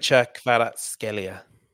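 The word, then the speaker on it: Khvicha Kvaratskhelia: Kvicha Kvaratskhelia.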